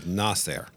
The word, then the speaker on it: Nasir: And it's about um, Nasir? Nasir. I had to learn how to pronounce it correctly. The Persian pronunciation puts the emphasis on the first syllable, Nasir.